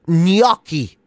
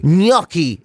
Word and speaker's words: gnocchi: Apparently, you're supposed to put a Y in it. So apparently, it's like gnocchi. – Gnocchi. – Gnocchi is supposed to be how you pronounce that.